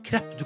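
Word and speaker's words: crêpe: Eh, og eh, det som er gøy, er at det området her er kjent for å være opprinnelsen til krepp. – Nei! Oi! Eller er det sånn det uttales? La oss høre. Krepp. Ja, ja.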